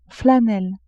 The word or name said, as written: flanelle